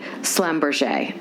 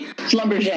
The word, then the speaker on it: Schlumberger: and even jewelry by Slumberger. I'm probably um, not saying that correctly. – Schlumberger.